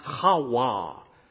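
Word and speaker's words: Hawa (חוה): The actual Hebrew pronunciation of Eve is pronounced Hawa.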